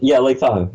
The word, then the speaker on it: Tahoe: Am I saying that wrong? – Lake Tahoe. Yeah, no, well, right. Hey, I got it right for once.